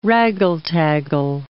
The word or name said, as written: raggle-taggle